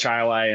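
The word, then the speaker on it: Chili: First of all, how far do you actually live from Chi-Lai, New York, and is it pronounced that way? – It is pronounced Chi-Lai, and I'm about 20 minutes away.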